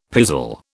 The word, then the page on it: pizzle: British English